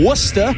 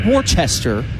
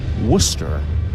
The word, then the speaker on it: Worcester: So West, who was the Diamondbacks' 12th round pick 2 years ago out of Boston College, he's from Massachusetts, from Worcester, not Worcester. Looks like Worcester, but it's pronounced Worcester.